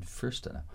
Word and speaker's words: Fürstenau: Fürstenau. – How do you spell that? – F-U with an umlaut, R-S-T-E-N-A-U. – And does that person have a first name? – Anton. – A first name, Anton Fürstenau.